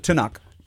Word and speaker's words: Tanakh: Tanakh.